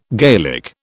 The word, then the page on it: Gaelic: British English